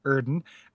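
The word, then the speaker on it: Oedon: Scott says, Erden is pronounced Erden because it's using the German umlaut O-E sound, usually represented by an O with an umlaut over it.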